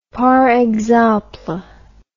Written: par exemple

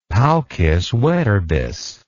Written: paucis verbis

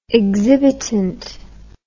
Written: exhibitant

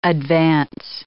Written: advance

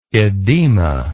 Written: oedema

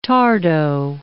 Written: tardo

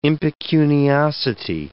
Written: impecuniosity

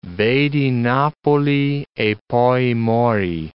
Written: vedi Napoli e poi mori

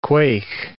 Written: quaich

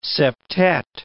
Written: septet

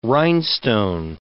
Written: rhinestone

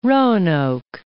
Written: Roanoke

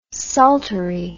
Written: saltery

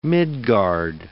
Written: Midgard

